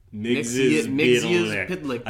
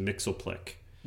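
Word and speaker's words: Mxyzptlk: Mixius Pidlick. I had to learn it because I used to say it like Super Friends, which was for the dummies where it was a little simplified. It was just Mixoplick.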